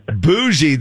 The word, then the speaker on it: bougie: B-O-O-S-H-I-E. – F-H-I-E. Bougie. This is a weird one because it's spelled B-O-U-G-I-E. – Bougie.